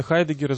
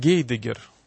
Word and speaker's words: Хайдеггер: Но любопытный факт, о котором, возможно, не знают наши радиослушатели, то, что изначально имя Хайдеггера звучало по-русски иначе, в соответствии с правилами транслитерации, его произносили как Гейдеггер.